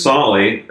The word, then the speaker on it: Solle: It's Solly, S-O-L-L-E, but they see it and say Soul a lot of the time.